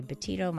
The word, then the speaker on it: Betito: And, of course, on social media, at Dr. Laurie Betito. My last name is spelled B-E-T-I-T-O.